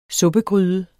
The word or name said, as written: suppegryde